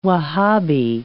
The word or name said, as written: Wahabi